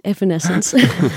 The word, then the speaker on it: Evanescence: Help me, hoe spreek je dat eigenlijk uit? – Ja, ik heb het net nog even opgezocht op YouTube. En het is dus Evanescence.